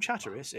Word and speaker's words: Chatteris: But you can tell the locals from the non-locals because the T's are pronounced. If you are from Chatteris, it's Chatteris. If you are not Chatteris.